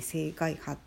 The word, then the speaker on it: 青海波: で今日締めてる方はこれは「青外波」っていう柄なんですけど「青い海の波」って書いて「青外波」って読む